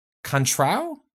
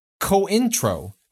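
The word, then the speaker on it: Cointreau: And contrao? Contrao? Co-intro. Correctly. But by God, have I insisted on pronouncing them improperly, despite friends and peers correctingly, incessantly.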